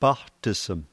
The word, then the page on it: paqtism: slow